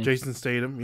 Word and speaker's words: Statham: Jason Statham, is that how you say his name? – Jason Statham, yeah.